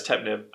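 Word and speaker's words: Ptemenib: Also, the P's probably silent. – Yeah, the P-T is silent. Yeah, it's Tepnib. So Tepnib. So, sorry for mispronouncing.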